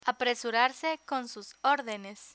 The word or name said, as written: apresurarse con sus ordenes